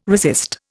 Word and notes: resist: Indian

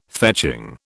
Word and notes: fetching: British English

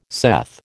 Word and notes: saith: American English

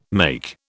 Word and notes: make: British English

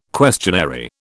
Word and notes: questionary: British English

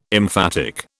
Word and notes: emphatic: British English

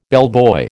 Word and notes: bellboy: American English

